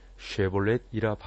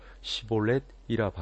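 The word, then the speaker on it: 쉽볼렛: 쉐볼렛이라 발음하라 하여 에브라임 사람이 그렇게 바로 말하지 못하고 시볼렛이라 발음하면 길르앗 사람이 곧 그를 잡아서 요단강 나루턱에서 죽였더라.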